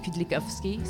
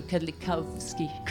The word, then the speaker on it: Kudlikowski: You would say Kudlikowski, so Kudlikowski.